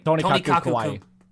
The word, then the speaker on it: Tonikaku Kawaii: Because if you look at something like Tonikaku Kawaii... I can never say that right. – Tonikaku Kawaii.